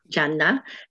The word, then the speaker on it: Gianna: I thought, how appropriate, because here's St. Gianna, or John, how do you say it in Italian? – Gianna.